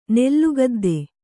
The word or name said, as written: ನೆಲಗೊದ್ದ